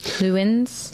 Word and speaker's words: Leeuwen: Van Leeuwen's, I never know how to pronounce this, Leeuwen's. – Leeuwen's.